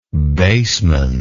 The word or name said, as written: baseman